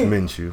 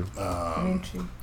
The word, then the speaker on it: Minshew: It's Minshew. Minshew. Like a cashew.